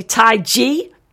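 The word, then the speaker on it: Tadhg: We've got Wendy Shea with One Goal, Sexy Tarding, I think, but it says it's pronounced Sexy Tai G, T-A-D-H-G, who knows, with a track called The Queen of the Carlo Town.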